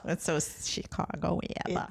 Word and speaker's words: ABBA: ABBA.